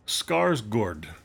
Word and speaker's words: Skarsgård: Stellan Skarsgård. Skarsgård. Like a gourd.